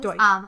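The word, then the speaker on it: Deutch: is they always pronounce her last name wrong. Zoe Deutsch or Dutch, mm-hmm. or whatever it is. – Deutsch.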